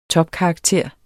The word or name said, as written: topkarakter